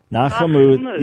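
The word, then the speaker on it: Nachamu (נחמו): Nachamu. Yeah, right. So just divide it into three. Na, and then the C-H-A, Cha, and then M-U, Mu. Nachamu, and the accent is on the last syllable.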